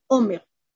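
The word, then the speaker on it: омер (עמר): Видите, охель, омер. Видите, это всегда в какой-то мере оно пишется без вава, и ударение у него на первом слоге.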